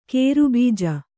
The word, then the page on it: ಕೇರಬೀಜ: kannada